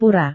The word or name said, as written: pura